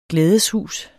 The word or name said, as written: glædeshus